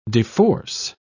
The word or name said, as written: deforce